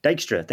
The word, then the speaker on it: Dijkstra: Dijkstra, thank you.